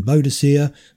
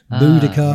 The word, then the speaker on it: Boadicea: Both, either pronunciation, Boadicea, Boadicea. – Ah, right, yeah. Sorry, I've heard a lot more about Boadicea. – Yeah. – Is it Boadicea? Right, okay. I've always thought it was Boadicea. – Yeah, Boadicea. Yeah, probably your pronunciation is probably closer to the original one. Boadicea, right. I guess it's the Latinized version of it, and Boadicea would be the British version of it.